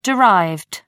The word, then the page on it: derived: American English